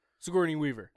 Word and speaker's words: Sigourney Weaver: Most like not all, but like Signor, Riley's brought up Sigourney Weaver, however the hell you say her name. – Sigourney Weaver.